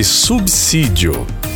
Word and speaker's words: subsídio: Então a palavra correta, a pronúncia correta chama-se subsídio, tá? Então é dessa forma.